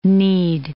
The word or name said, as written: kneed